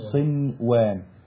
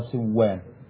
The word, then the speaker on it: صنوان: أمال هنا ما دخلت النون على الواو وعلى الياء قنوان دنيا صنوان. طيب هنا الكلمات ديت دونا عن بقية الكلمات القرآنية والأحكام دي لوحدها. فاش أقول ديا ولا قوان ولا صوان، لازم تنطق هكذا بالإظهار، دنيا وقنوان وصنوان.